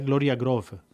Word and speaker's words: Gloria Groove: De fet, mm, com que és brasilera, em sembla que es pronuncia Gloria Groove.